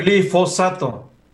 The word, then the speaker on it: glifosato: Ahorita no, porque en este tema se están peleando por el uso del glisofato, que espero que sí lo esté diciendo bien, porque siempre... – Glifosato. – Ah, ah, fíjate, lo dije mal, glifosato.